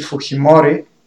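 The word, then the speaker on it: Fujimori: 90-та година до тогава неизвестния агроном от японски происход Алберто Фуджимори на испански Фухимори, така е познат в Перус това произношение –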